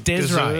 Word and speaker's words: Desree: No, but it's spelt D-E-S-R-E-E, Kate. So it's Desray.